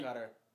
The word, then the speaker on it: Qatar: You can actually pronounce it either way. – I know you can. Like saying Qatar better. – It's like I've never heard anybody say that. – A lot of people pronounce it Qatar. – Officials from Qatar came out and said it's pronounced both ways.